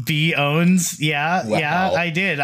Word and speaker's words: pwns: But you're not supposed to pronounce the P. So it's pronounced B owns.